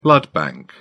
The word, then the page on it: blood bank: British English